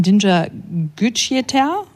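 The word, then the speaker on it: Dinçer Güçyeter: Du hast es erzählt, unser Deutschlandmärchen basiert auf dem Roman von Dinger Gücüter. Sag, wenn ich es falsch ausspreche. – Perfekt.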